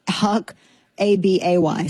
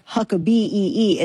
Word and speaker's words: Huckabay: Now, I'm not saying it like Britney Spears. It's spelled like Huck, A-B-A-Y. So not like Huck B-E-E. It's Huck Abay, H-U-C-K-A-B-A-Y. See the difference? Huck Abay, Huck B, very different.